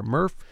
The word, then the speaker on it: MRF: A great place to start with this is I went to the sorting facility in Montgomery County, the Materials Recovery Facility, or MRF. – Stop rhymes with Smurf.